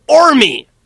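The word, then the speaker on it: Qormi: I say it again, I show it to him on the piece of paper, he says, oh, Ormi! Yes, we can go to Ormi! – Ormi. – Starts with a silent Q.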